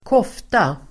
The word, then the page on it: kofta: ²k'åf:ta